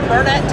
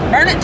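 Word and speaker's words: Burnet: So as I understand it, June 7, 8, and 9 at Reveille Peak Ranch in Burnett, Texas. Did I say that right? – Yep. – Burnett, Texas is when the match is.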